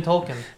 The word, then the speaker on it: Tolkien: I thought it was just pronounced Tolkien. – Tolkien. – Right? Well, it's always been Tolkien.